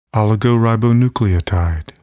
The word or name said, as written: oligoribonucleotide